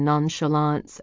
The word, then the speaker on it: nonchalance: She couldn't remember what she'd said, but low snickers had traveled around the room when she mispronounced the CH and nonchalance as a hard K sound.